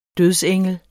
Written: dødsengel

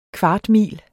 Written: kvartmil

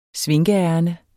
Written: svinkeærinde

